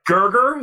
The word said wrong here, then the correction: Kurgan: is that right? – That's Kurgan. how you say his name.